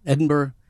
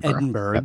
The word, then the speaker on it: Edinburgh: As Rod in Edinburgh, I believe I'm pronouncing that correctly, has done. Edinburgh, yes, that is the Scottish method of pronunciation. – Right, not Edinburgh. Yep. – No, that's a good way to give yourself away as a non-Scot. – That's true.